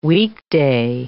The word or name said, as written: weekday